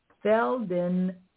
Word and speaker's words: Feldenkrais: He's also an expert in stage combat and, now I'm going to say this and I'm going to kill it, but I'm going to try to say it right. Feldenkrais movement. Now, that's it. You got it, girl.